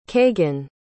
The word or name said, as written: kagan